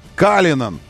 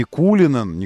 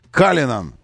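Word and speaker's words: Cullinan: Да, кроссовер Rolls-Royce называется Калинан. Калинан. Вот они настаивают, что не Кулинан, не Кулинан, а Калинан. Вот так, на английский манер нужно произносить его название.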